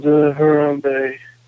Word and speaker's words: Harambe: They killed Harambe. – Harambe? – They just killed him. R.I.P. Harambe.